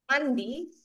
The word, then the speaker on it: mandi: we have mandi to shower.